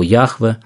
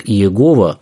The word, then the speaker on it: Яхве: Мы не знаем в точности, как оно произносилось, скорее всего, Яхве. В наше время традиционно понимают его произношение как Иегова, но, пожалуй, с уверенностью можно сказать, что именно так оно не произносилось.